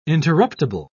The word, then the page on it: interruptible: British English